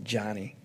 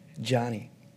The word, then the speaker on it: Joni: But that's when my friend wisely said, Joni, actually, I think she pronounces it Johnny. Johnny.